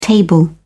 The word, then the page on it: table: British English